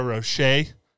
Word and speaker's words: Rocher: Is there an L on the end of the word? – No. – Oh, no. – Not at all. – There's no L on the end of that word. Are you for real? – I'm positive. It's spelled R-O-C-H-E-R. – But they don't pronounce the R.